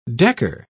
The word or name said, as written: decker